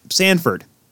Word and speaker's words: Sanford: Ryan O'Reilly, after a sick pass between the legs by Zach Sanford.